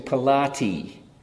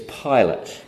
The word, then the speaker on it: Pilate: My mother, my mother calls him Pilati, and that's not how to pronounce it. It's Pilate, just to make clear.